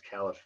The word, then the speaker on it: Caliph: There was a Salif, am I saying that right? Salif, a Caliph, I think it's Caliph.